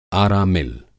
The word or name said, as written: आरा मिल